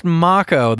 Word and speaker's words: Mako: You know, it turns out it's pronounced Mako.